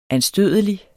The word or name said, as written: anstødelig